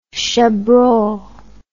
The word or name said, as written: chabrol